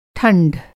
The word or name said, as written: ठंढ